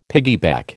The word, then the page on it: piggyback: American English